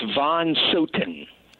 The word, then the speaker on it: Von Sothen: Yes, it's Von Sothen. – Von Sothen. I grew up in Germany. I should Silent H.